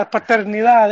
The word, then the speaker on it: paternidad: And then, you know, he piled on the misery to Cruz Azul, and you know, he kept the, you know, the patronat, how you say it? – It's paternidad. Su papá. – Yeah, paternidad. There you go.